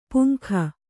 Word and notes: ಪುಂಖ: puŋkha

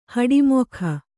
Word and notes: ಹುಟ್ಟಮೂಕ: kannada

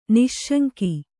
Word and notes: ನಿಃಶಂಕ: nih śaŋka

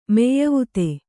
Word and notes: ಮೆಯ್ಯವುತೆ: meyyuvute

